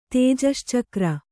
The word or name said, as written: ತೇಜಶ್ಚಕ್ರ